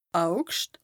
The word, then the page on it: Augscht: Bas-Rhin; Strasbourg